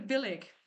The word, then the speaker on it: Billig: Banana, Banana Nationalism von Michael Billing. – Ist es überhaupt Michael Billing? Ja, Michael Billig, nicht Billing. Billig.